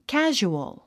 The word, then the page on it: casual: kǽʒuəl; カジュオゥ